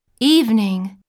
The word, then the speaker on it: evening: Evening Evening Evening は単語の最初の文字を E と伸ばして発音するよ。